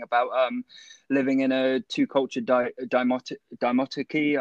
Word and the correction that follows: dichotomy: I think that's how you pronounce dichotomy. – It. Sorry. Dichotomy, yes.